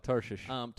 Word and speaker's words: Tarshish: Tarshish. – Shish. Yeah, we'll just... – Shish. That's how you say it.